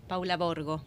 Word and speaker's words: Paula Borgo: Paula Borgo.